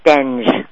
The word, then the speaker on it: Stenge: Nope, it's Stenge.